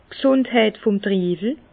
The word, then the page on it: d' G'sùndheit vum Triiwel: Bas Rhin; Herrlisheim